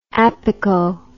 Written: apico

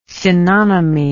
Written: synonymy

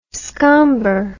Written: scomber

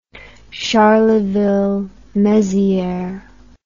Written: Charleville-Mézières

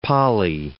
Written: poly

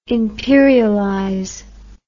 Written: imperialize

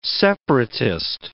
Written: separatist

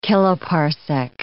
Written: kiloparsec

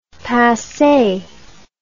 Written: passee

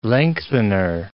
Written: lengthener

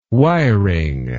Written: wiring

